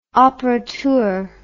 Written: operateur

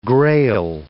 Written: grail

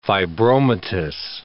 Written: fibromatous

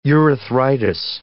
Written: urethritis

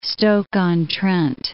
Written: Stoke-on-Trent

